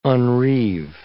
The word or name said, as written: unreeve